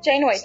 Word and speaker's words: Janeway: maravilhosa Janeway, que eu falei certo? Janeway, tá certo.